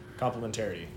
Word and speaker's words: complementarity: There's too many syllables. It's like complementary, but with an extra complementarity.